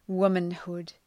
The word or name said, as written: womanhood